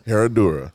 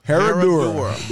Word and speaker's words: Herradura: Pronounce it again. – Herradura. – Herradura. There you go.